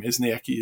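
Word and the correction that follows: Eskinazi: is it? – Eskenazi. – Eskenazi.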